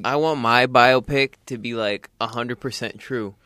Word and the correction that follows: biopic: You know it's actually pronounced biopic? – Biopic? – Yes, like biology.